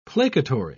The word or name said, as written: placatory